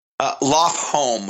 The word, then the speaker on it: Lofholm: Lofholm, home like house.